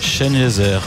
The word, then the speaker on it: Sengezer: Et alors que sur le banc, on a le numéro 24, Mohamed Sengezer. Et ouais. Ça se prononce comme Ebenezer, le Z. – Chenezer, ouais.